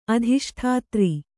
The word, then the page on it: ಅಧಿಷ್ಠಾತ್ರಿ: Kannada